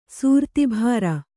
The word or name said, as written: ಸೂರ್ತಿಭಾರ